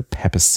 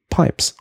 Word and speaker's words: Pepys: Zu Beginn der Folge zu Paps habe ich den Schriftsteller Samuel Pepys zitiert. Nur dass Hörer Sebastian mich richtigerweise darauf hinwies, dass der Nachname tatsächlich Pipes ausgesprochen wird.